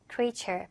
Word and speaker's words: creature: 발음 주의해 주시고 creature 아니더라. creature 바로 창조물, 생물이란 뜻이 있고요.